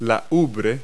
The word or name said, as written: udder